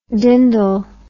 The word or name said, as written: dindle